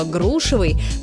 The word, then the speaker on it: грушевый: Но вернемся к грушам и запомним, что ударение во всех формах прилагательного «грушевый» падает на первый слог, как в слове «груша».